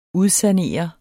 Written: udsanere

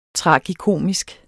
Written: tragikomisk

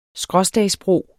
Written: skråstagsbro

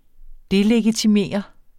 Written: delegitimere

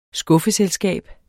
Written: skuffeselskab

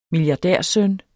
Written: milliardærsøn

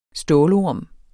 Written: stålorm

